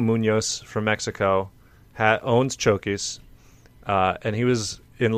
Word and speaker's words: Chokis: His name is his name is Chokis. – Chokis.